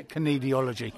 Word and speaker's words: kinesiology: Tell me, kinesiology. It's like knees with pronouncing the K, isn't it? Yeah, kinesiology.